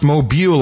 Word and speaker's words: Mobile: He even says it's pronounced mobile like automobile, not like mobile home.